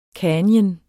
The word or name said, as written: canyon